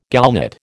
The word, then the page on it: gallnut: American English